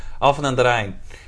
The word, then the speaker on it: Alphen aan den Rijn: I said, Alphen aan den Rijn. And he said, are you Dutch? You know, he said, you pronounce that really well.